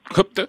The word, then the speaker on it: Qutb: Celui qui a marqué beaucoup sa pensée et qui d'une certaine manière a déclenché aussi son engagement djihadiste, c'est un autre penseur, Sayyid Qutb. Alors je ne sais pas si je prononce correctement, Sayyid Qutb. – Qutb. C'est QTB.